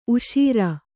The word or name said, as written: ಊಷರು